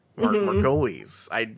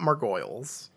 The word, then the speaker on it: Margolyes: Margolyes. Margolis. It's not Margoyles. I will say that.